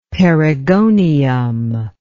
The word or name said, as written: perigonium